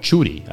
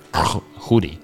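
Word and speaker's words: Tschudi: There was a guy called Chudi. I think his name was like T-S-C-H-U-D. And if I know how Swiss Germans pronounce it, something like Hudi.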